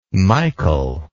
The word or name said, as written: Michol